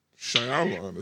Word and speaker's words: Shyamalan: Shyamalan is, I think, how you say it.